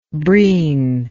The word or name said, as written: breen